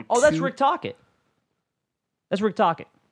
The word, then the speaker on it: Tocchet: Yeah, that's Rick Tocchet. I had to see it. I couldn't find it. No, that's how you say it. It's Rick Tocchet.